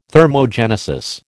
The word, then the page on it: thermogenesis: American English